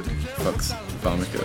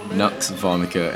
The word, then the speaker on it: Nux Vomica: It's off their latest album, Nux Vomica, or is it Fux Vomica, or something like that. Let me see how it's pronounced. It is Nux Vomica, N-U-X-V-O-M-I-C-A, yes.